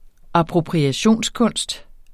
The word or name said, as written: appropriationskunst